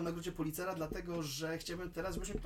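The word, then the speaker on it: Pulitzer: Y, nie przez przypadek wspomniałem o nagrodzie Pulcitera. W, w, w, A nie policera? – Policera. przepraszam, tak, oczywiście, że nagrodę policera.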